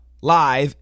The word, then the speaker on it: live: The article live, L-I-V-E. Now, don't say live. It's the same spelling, different meaning, different pronunciation. Live, L-I-V-E, wire, W-I-R-E.